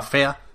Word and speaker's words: Fe: Los del Fe, que se pronunciaba fea, nos dijiste, Marta.